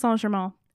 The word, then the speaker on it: Saint-Germain: But Saint-Germain, that's how I'm going to pronounce it. It is pronounced Saint-Germain.